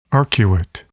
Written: arcuate

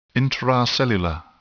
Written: intracellular

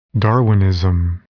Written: Darwinism

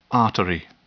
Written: artery

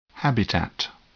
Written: habitat

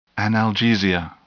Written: analgesia

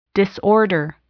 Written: disorder

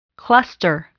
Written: cluster

